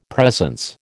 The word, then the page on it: presence: American English